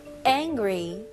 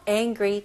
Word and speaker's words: angry: ang 바로 ng 그 발음으로 가셔야 돼요. n 발음이 아니고요. angry. angry. angry. 강세가 어디 들어가죠? 네. angry. 네. 첫 번째 음절에 들어가죠.